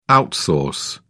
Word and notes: outsource: British English

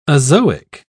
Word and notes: azoic: British English

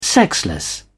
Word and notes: sexless: British English

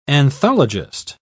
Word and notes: anthologist: British English

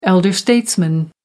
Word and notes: elder statesman: American English

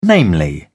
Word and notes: namely: British English

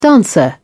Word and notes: dancer: British English

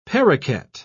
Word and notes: parroket: British English

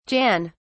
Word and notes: Jan: British English

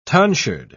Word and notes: tonsured: British English

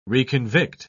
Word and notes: reconvict: British English

